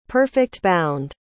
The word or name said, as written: perfect-bound